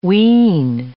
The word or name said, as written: ween